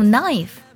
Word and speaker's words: knife: 第一组呢，就是 k n 开头，k 不发音，像 know 知道，还有 knife 刀子都是这样的。